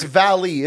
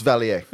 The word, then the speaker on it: Valier: maybe it's Valley. Isn't the ER like silent sometimes? – It's a silent R. – That would be Valier. – It's a silent R. – It's Valier.